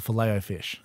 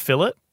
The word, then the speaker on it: Filet: That's what it's called, the Filet-O-Fish. – Okay. – That's how you pronounce that word. – Filet. Yeah. – Filet. – It's not fillet? – I don't think so.